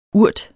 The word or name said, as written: urt